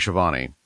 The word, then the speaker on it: Schiavone: The announcer's name is Tony Schiavone. It's spelled S-C-H-I-A-V-O-N-E.